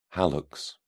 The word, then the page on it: hallux: British English